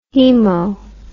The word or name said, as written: hemo